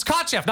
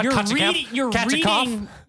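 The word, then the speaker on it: Kotcheff: It's Kochev, not Kachikov.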